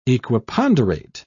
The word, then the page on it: equiponderate: British English